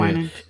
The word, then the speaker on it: Winans: That's right? That's how you say his name? Yeah. Winans.